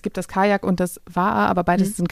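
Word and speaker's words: Va'a: Man hört immer so viel von Kanu, Kajak und ihr habt noch eine andere Bootskategorie, wie spricht man sie aus? – Waa. – Waa, okay. – Genau, die kommt aus dem Hawaiianischen, glaube ich.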